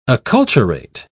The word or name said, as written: acculturate